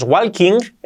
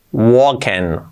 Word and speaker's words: walking: Pero cuidado, la L no se pronuncia, no es walking, ¿no? Walken, walken, walken. Wa, luego ken, la I como una E y la G casi no se pronuncia, walken.